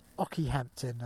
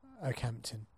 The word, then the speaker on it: Okehampton: Now I want to call it Ockyhampton or Okeyhampton, Ockyhampton, um, but it's not pronounced that. I have a reliable source um, of a friend uh, and um, temporary line manager called Kat who knows she's from this neck of the woods, so she knows it's called um, Okehampton.